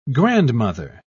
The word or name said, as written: grandmother